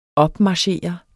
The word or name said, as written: opmarchere